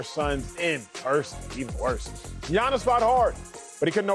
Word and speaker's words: Giannis: Say his name in homeland. Uh-huh, it's Giannis.